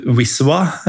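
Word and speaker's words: Wisła: Eh, det er Krakowia mot eh, Wiswa, ja, uttales det jo. – Det eh. uttales, ja. Som er elven, er det ikke det? Jo. Eh, ja, vis, visla, visla Krakow, ville jeg sagt.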